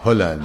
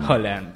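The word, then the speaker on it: Haaland: Y a Holland. – Holland. – Avisamos que se pronuncia así.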